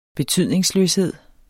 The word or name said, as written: betydningsløshed